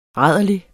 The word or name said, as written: rærlig